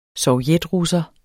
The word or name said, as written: sovjetrusser